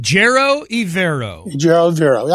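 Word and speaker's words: Ejiro Evero: Ejiro Ivero.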